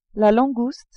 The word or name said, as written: langouste